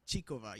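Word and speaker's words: Krejčíková: I think with Krejcikova, hopefully I'm pronouncing it right. You are. That's perfect. – Krejcikova, yeah.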